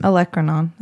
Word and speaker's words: olecranon: And then Urban Dictionary says it's called the olecranal skin. – Olecranon. That's what olecranon. Yeah, that's right. Mm-hmm. I said that wrong.